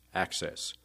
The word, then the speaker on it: AHCCCS: or AHCCCS, pronounced ACCESS.